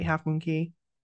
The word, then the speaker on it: Half Moon Cay: But I will say, like, cause during the day the cool thing about this is we're going to Half Moon Cay, right? I'm saying that right? Half Moon Cay.